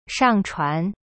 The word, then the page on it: 上传: shang-chuan